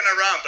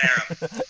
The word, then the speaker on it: Aram: So, I look like an Aram, but I'm an Aram.